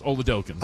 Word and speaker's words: Oladokun: The official pronunciation is Oladokun.